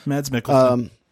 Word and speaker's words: Mads Mikkelsen: It was directed by Joe Pena, and it stars Mads Mikkelsen. I'm not entirely sure how to say his name. – That's right. Mads Mikkelsen.